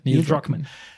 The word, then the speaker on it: Neil Druckmann: Und Neil Druckmann, habe ich es richtig ausgesprochen? – Ja, Neil Druckmann.